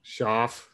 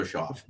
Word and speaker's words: Shaw: This is, I don't, he, they bring him in to push Shug or Shaw. How is it? – Shaw. Shaw. – Because it's spelled like cough. – I mispronounce names on purpose anyway. So let's go Shaw.